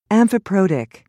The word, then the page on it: amphiprotic: American English